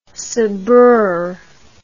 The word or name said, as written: sabreur